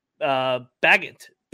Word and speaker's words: Bagent: What do you think of the backup, Tyson Bagent? I can't pronounce it. Agent. Special Agent. Bagent.